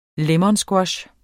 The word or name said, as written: lemonsquash